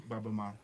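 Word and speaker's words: Baba Maal: Baba Mal.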